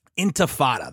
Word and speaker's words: Intifada: Intifada.